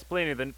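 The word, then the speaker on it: Plini: Yes, Pliny, the artist whose name I can never pronounce.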